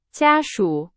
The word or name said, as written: 家属